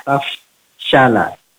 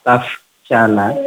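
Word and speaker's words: tafshala (تفشلا): Pertama, pada ayat 122, kalimat tafshala, tafshala, nah, fa-nya ada udara yang mengalir, taf, taf, karena tadi terdengar taf, tapi taf, tafshala, tafshala, kemudian yang kedua, pada ayat 124, tadi sudah bagus iztaku, awalnya dibaca iztaku, tapi diperbagi lagi menjadi iztaku.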